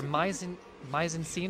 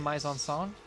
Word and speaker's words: mise en scène: The film majors are going to come after me for this, but it's either mise en scène or mise en song, the correct pronunciation,